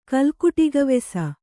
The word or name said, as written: ಕಲ್ಕುಟಿಗವೆಸ